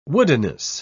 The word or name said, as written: woodenness